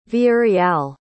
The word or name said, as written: villareal